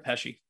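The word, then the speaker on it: Pesce: Is it Pesky? – Brett Pesci. – Pesci, sorry.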